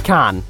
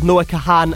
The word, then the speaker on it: Kahan: And then, well, turns out everyone's quite quick to point out that his name is just Noah Kahan. As in, like... Can, like how every other name Can is pronounced. But in fairness, his is spelled K-A-H-A-N, and there's an extra A in there, which is my stumbling block.